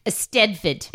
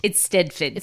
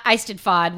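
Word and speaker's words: Eisteddfod: It is pronounced Estedford. – It's Stedford. – It's not Eisted-fod, which we knew it wasn't, but we didn't know.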